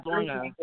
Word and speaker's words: Lorna: Element is, alright, so my first name is actually Luna. Like the cookie Luna.